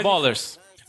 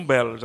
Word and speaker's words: Ballers: Estou vendo muita gente pronunciar como Bellers. Aí não, tá? Bellers? Bellers não pode. não, Bellers não. É, é simplesmente errado.